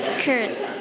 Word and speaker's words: skirt: Okay. บางคนอาจจะออกเสียงว่า skirt is incorrect. Okay, no. จำไว้ว่าถ้าตัว k อยู่ตามหลังตัว s เนี่ยจะ sound like กอไก่ Okay, no.